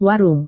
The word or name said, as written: warung